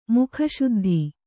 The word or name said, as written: ಮುಖಶುದ್ಧಿ